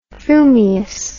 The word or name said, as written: frumious